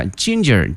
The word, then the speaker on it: ginger: 这个姜这个词同学们记一下， ginger G-I-N-G-E-R、哎、它的发音同学们一定要注意，第一个这个 G 呢发音成这个 ginger、啊、 ginger and garlic 还有大蒜。